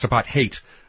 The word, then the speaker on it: apartheid: And by the way, it's pronounced apartheid. Just like hate.